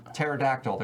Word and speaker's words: pterodactyl: Yeah, it's pronounced pterodactyl. There's a silent P there. P before T.